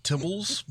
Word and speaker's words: Tibballs: T-I-B-B-A-L-L-S. You could say Tibballs or Tibballs. If there's two B's, like Jeff Tibballs. Jeff Tibballs. – I think it might be more pronounced Tibbles, but yeah, let's just run with it.